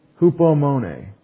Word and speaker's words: Hupomone: H-U-P-O-M-O-N-E. Long E. That's a European or Greek E, A. It says A. All the other languages that are kind of like English kind of say the long A sound in English for the long E.